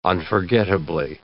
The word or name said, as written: unforgettably